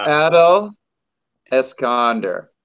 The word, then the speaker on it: Eskelander: It's Adele Eskelander. So you got to put the accent on the first syllable.